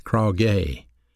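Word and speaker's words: krauge (κραυγή): Well, that word clamor is another Greek word pronounced krauge, K-R-A-U-G-E, krauge.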